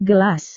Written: gelas

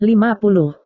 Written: lima puluh

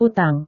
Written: utang

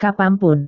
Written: kapan pun

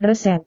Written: resep

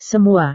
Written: semua

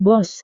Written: bos

